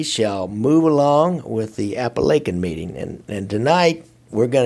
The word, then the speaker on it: Apalachin: If you remember last week, we learned how to pronounce Apalachin. It's not Appalachian, it's Apalachin.